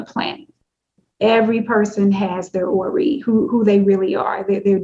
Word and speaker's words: Ori: And she said, they did something for my Ori. And you spell that O-R-I.